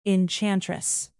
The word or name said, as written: enchantress